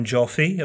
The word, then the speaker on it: Joffe: His name is Ben Joffe.